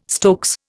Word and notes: stokes: Indian

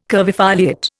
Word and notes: curvifoliate: Indian

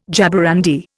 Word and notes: jaborandi: Indian

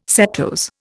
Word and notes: setose: Indian

